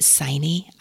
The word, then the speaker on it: Signe: Her name is, I'm going to pronounce it Signe. I don't know how to pronounce it in Norwegian, but mm-hmm. Signe Pike. The author spells it the same way, and that's how she pronounces her name.